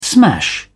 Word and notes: smash: British English

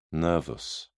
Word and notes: nervus: British English

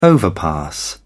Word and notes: overpass: British English

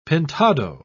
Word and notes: pintado: British English

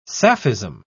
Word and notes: sapphism: British English

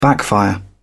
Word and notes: backfire: British English

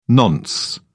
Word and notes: nonce: British English

